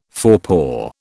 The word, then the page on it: forepaw: British English